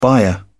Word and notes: byre: British English

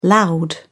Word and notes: loud: British English

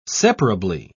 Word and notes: separably: British English

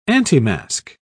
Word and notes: antimasque: British English

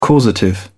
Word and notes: causative: British English

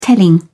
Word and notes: telling: British English

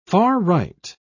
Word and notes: far-right: British English

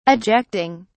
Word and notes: ejecting: British English

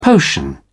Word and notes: potion: British English

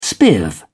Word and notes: spiv: British English

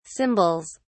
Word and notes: cymbals: British English

